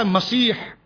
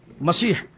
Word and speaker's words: مسیح: اس کے ساتھ حضرت عیسیٰ کے ایک لقب آتا ہے مسیح, لقب آتا ہے مسیح, اور دجال کے لیے بھی لفظ آتا ہے. لیکن بزرگوں نے اس کے تلفظ میں فرق بیان کیا کہ حضرت عیسیٰ کے لیے جو مسیح